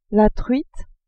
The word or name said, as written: truite